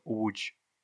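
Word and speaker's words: Łódź: По-польски этот город произносится Удж.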